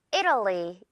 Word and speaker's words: Italy: 이탈리아가 아니라 이탈리에서 끝나는 느낌 느껴지죠? 자, 그래서 발음 어떻게 하냐면요. Italy 이렇게 됩니다. Italy. 이탈리가 아니라 를 이렇게 이 TAL 부분이 약화가 된다고 보면 돼요.